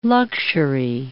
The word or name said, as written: luxury